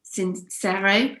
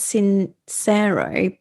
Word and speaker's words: Sincero: Sincero, I think that's how I think she pronounces it as Sincero, but it's an Italian surname. So I always say Sincero.